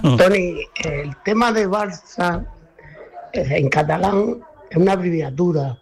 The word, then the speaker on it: Barça: Y es Barça, pero se escribe Barça, con C de casa. Pero se pronuncia S, esa C en catalán se pronuncia S.